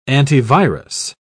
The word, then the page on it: antivirus: British English